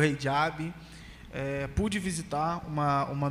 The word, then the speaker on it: hijab: Eu cheguei na cidade, vi algumas mulheres usando o Eihab, pronúncia está correta? Como é que é, o Pablo, isso aí? Você que é um grande conhecedor. – Hijab. Hijab, usando Hijab.